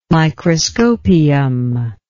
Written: Microscopium

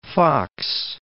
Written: Foxe Basin

